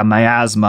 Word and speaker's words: miasma: It broke the pall of assimilation that had been sitting like a miasma on queer people. I don't know how to pronounce miasma, apparently. – You nailed it.